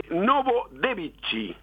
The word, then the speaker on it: Novodevichy: El cementerio de Novodevichy.